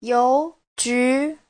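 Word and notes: youju: you2ju2

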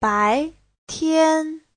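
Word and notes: 白天: bai2tian1

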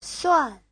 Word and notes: suan: suan4

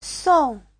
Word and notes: song: song4

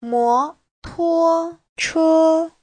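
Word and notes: 摩托车: mo2tuo1che1

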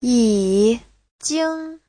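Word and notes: yijing: yi3jing1

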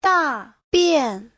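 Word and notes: dabian: da4bian4

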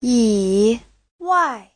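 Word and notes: yiwai: yi3wai4

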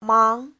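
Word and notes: 忙: mang2

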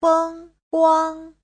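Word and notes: fengguang: feng1guang1

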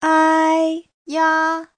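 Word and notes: 哎呀: ai1ya1

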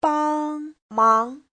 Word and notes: 帮忙: bang1mang2

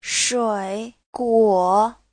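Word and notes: shuiguo: shui3guo3

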